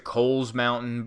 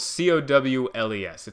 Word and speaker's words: Cowles Mountain: Some people pronounce it Coles Mountain, but nobody likes them. Okay, it's spelled C O W L E S. It's Cowles. Some people go, it's Coles, and I go, you have no friends, and this is partially the reason.